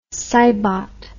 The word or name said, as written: cybot